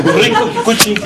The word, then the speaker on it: Rinko Kikuchi: Rinko Kikuchi.